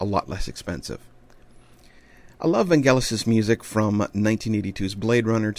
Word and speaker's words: Vangelis: Now, before I get a huge amount of email saying it's pronounced Vangelis and that's all, you know, no, I have it on very, very, very good authority that it is pronounced, in fact, Vangelis.